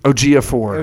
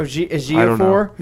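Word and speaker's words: Ejiofor: Ogeefor. – Ogeefor.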